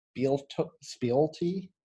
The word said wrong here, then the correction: Spieltoe: Spieltoe. It's spelled Spieltoe. S-P-I-E-L-T-O-E.